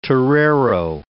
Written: torero

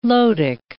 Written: lotic

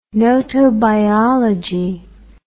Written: gnotobiology

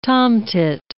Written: tomtit

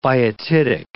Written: biotitic

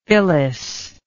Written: phyllis

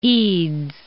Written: Eads

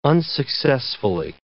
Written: unsuccessfully

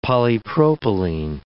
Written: polypropylene

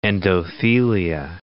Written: endothelia

